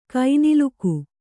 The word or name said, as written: ಕೈನಿಲುಕು